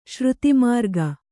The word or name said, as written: ಶ್ರುತಿಮಾರ್ಗ